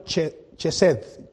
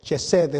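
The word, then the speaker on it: chesed (חסד): Y esta es la palabra, hermanos, es la palabra, ah, se escribe en, español chesed, chesed es la palabra, ¿verdad? C-H-E-S-E-D-H, e e chesed.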